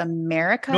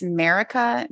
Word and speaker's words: Maryka: And I'm not sure if I'm pronouncing her name right, but it's like, it's spelled M-A-R-Y-K-A. So America. – No, M-V-Y-R-K underscore. – Yeah. That's her Instagram handle, but it's America, M-A-R-Y-K-A.